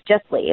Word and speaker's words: JessLee: And my name is actually, it's pronounced like Leslie, like Leslie's. It's Jesslee. It's all one word.